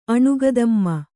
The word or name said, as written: ಅಣುಗದಮ್ಮ